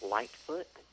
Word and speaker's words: Lightfoot: That's spelled S-E-T-H. – Yep. – And my last name is Lightfoot. So yep. – L-I-G-H-T-F-O-O-T.